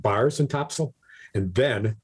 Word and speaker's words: Topsail: Hey, have you ever been to Topsail, North Carolina? – I have not. – Well, I've got to do a little research on it. I'm aware that it's pronounced Topsail and not Topsail.